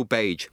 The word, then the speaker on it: Bage: I'm somewhat humbled to be in his presence, but I'm delighted, nevertheless, to welcome today's podcast guest, Neil Bage, with a hard G.